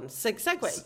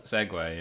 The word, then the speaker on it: segue: But so how do you pronounce the Segu one? Segway? – Segway, yeah.